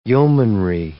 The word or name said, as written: yeomanry